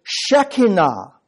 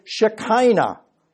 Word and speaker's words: Shekinah: In Greek, that word is skene, S-K-E-N-E, skene. If you were Hebrew, you wouldn't say it like that. And you would pronounce it Shekinah. Now we speak English, so we don't talk like Jews. So we say Shekinah.